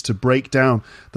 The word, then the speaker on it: break down: So when it's a noun, the first syllable is stressed, breakdown. And when it's a verb, the second part is stressed, to break down.